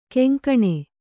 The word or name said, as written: ಘಿಂಘಿಣಿ